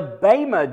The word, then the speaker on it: Bema: The The Bema judgment, that's really how it's pronounced, will take place in heaven while the tribulation is taking place on earth.